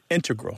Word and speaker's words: integral: Yeah, but both correct, just to clarify here, both correct, but the mathematicians and math-using scientists are more likely to integral with the stress on the first syllable.